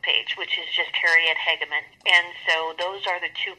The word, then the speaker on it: Hageman: Okay, so I am back, and we actually have Harriet Hageman, and I'm pronouncing the last name correct, Hageman? – Hageman.